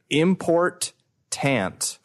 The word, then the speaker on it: important: First we have, Fulken Wolagen 62M who says, "Hey dude with the hat on, please pronounce the T in the word important. – Important. – Not so much like important, more so like import-tant.